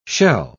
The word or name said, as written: shell